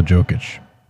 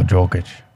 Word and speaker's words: Jokic: Isn't it a soft J? Is it not a soft J? – Probably is, dude. – Yeah. – I don't know. – Thank you. – Jokic? – Jokic. – Jokic? – Jokic. – Like I'm going for a Yog.